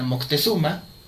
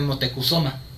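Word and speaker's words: Motecuhzoma: Sabemos que el emperador que, que estaba en esa época era Moctezuma, o de acuerdo a, las... Eh, se supone que el nombre como tal es, es, es españolizado, Moctezuma, la verdadera pronunciación debería ser Motecuzoma.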